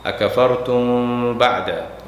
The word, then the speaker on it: أكفرتم بعد: আকাফারতুম বাদা, এই মিম সাকিনার পরে বা আসলে মিমটা উচ্চারণ হবে না, ওটার সাথে বায়কে লাগাই দিতে হবে।